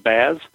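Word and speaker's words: BaaS: Yeah, it's Backup as a Service, pronounced Baz.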